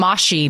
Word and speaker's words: Moshi: Oh, those mushy monsters. They're actually like moshy monsters. Bubbles. We're supposed to, we should know better to say it correctly.